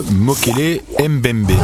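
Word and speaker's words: Mokele-mbembe: c'est le Mokele Mbembe. Est-ce que je prononce bien ? – Oui, vous prononcez très bien.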